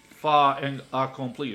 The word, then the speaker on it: fait accompli: In, and I think part of the reason is they want to present it as fait accompli. Is that how you say it? – Yes.